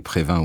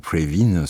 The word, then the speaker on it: Previn: La chanson, c'était Second Chance, musique André Prévin ou André Previn, selon la prononciation,